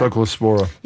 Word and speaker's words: Cyclospora: Cyclospora.